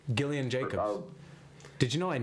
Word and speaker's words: Gillian: her name is pronounced Gillian Jacobs.